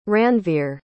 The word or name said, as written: ranvir